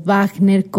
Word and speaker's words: Wagner: La Valquiria es la segunda de las cuatro obras que componen el ciclo de El Anillo de Nibelungo de Richard Wagner o Wagner, como se pronunciaría en alemán.